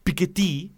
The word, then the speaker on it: Piketty: Piketty, pues yo le decía el Piketty.